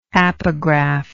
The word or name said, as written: apographical